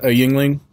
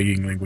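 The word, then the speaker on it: Yuengling: It's like Y-E-U-N-G-L-I-N-G maybe? I don't know. It's a weird spelling. – Are you sure it's pronounced Yingling? – That's the way I've always heard it pronounced.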